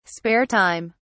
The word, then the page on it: spare time: British English